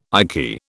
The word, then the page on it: icky: British English